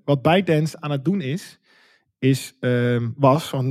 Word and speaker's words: ByteDance: ByteDance, en dat is de maker van TikTok, als ik het goed zeg. Zeg ik dat goed? – Ja. Zeker.